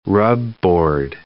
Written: rubboard

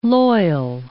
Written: loyal

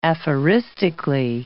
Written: aphoristically

